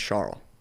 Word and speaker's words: Charles: His name is spelled like Charles, but it's pronounced Charles.